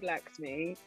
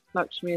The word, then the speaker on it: Laxmi: Yeah, so I always get called Lakshmi, but because it is spelled L-A-X-M-I, but I guess Lakshmi is like my stage name, isn't it? – Superb.